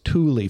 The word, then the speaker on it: Tule: So it's very possible that the name Jack was trying to come up with for the fog was Thule fog. T-U-L-E.